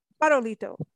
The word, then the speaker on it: Farolito: You and Wayne operate El Farolito Bed and Breakfast. Am I pronouncing this correctly? – That's correct. – Farolito.